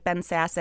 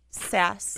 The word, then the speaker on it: Sasse: And the other thing is with people like Ben Sasse and Jeff Flake that I think gets left behind. – Is that how you say his name? – Sasse. – Ben, I thought it was Ben Sasse.